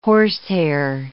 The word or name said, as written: horsehair